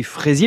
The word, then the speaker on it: Frazier: Le bien nommé Jean-Marie Fraisier, mais ça s'écrit F-R-A-Z-I-E-R, qui a ramené du Chili cette fraise toute blanche que nous présente un cultivateur.